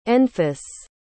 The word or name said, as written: enfys